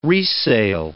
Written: resale

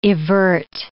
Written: evert

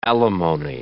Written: alimony

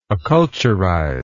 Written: acculturize

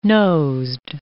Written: nosed

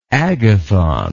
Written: agathon

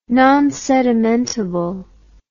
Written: nonsedimentable

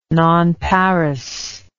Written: nonparous

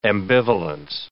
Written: ambivalence

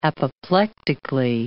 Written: apoplectically

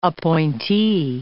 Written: appointee

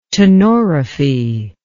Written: tenorrhaphy

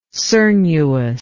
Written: cernuous

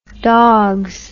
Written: dogs

